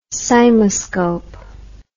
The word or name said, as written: cymoscope